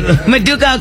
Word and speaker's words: Maduka: Maduka? I finally said it right. Maduka Okoye. – Oh, Maduka is right.